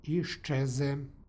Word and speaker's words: iščeze: stihu, u primjeru iščeze, dolazi do jednačenja suglasnika. Dakle, z prilikom izgovora prelazi u š, iščeze.